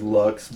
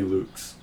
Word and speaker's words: Luchs: For whatever reason, our family says Lux, but really the pronunciation would be Luchs. – Right. – Luchs would be the more proper German pronunciation.